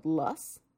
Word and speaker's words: loess: So one of the really fancy types of wind-driven deposits is called LUSS. It's a LUSS deposit, and it's spelled L-O-E-S-S.